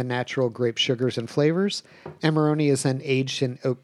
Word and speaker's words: Amarone: Amarone del Valpolicella. – I'm glad that you pronounced Amarone because I was just going straight with Amarillo.